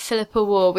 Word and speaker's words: Philippa Warr: I'm at Philippa War, which is P-H-I-L-I-P-P-A-W-A-R-R.